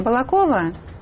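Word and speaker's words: Балаково: Простите меня, пожалуйста, за ударение. Балакова? – Балакова, ну, простите.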